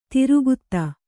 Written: ದೀರ್ಘತೆ

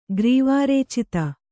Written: ಗ್ರೀವಾರೇಚಿತ

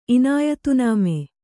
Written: ಇನಾಯತನಾಮ

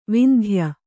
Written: ವಂದ್ಯ

